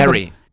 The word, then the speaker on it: Carrey: Carrey.